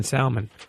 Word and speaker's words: salmon: Miss Lissa Knows said, I don't trust people who pronounce the L in salmon.